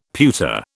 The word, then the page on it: pewter: British English